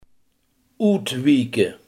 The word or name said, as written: uitwijken